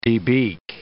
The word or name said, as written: debeak